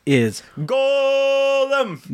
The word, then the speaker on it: golem: Is Golem. So Golem, not Golem, not Golem, Golem.